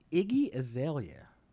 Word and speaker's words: Iggy Azalea: Pablo, Iggy Azalea.